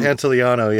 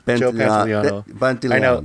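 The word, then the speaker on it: Pantoliano: Joe Pantaleone. I can't. Is that how you say his name? – Pantaleano, yeah. – Pantaleano.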